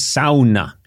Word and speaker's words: sauna: And thank you for saying it correctly, because here in the United States, people say, do you want to, I went to a sauna. – Sauna. – But I learned in Finland, it's sauna.